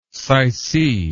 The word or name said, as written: sycee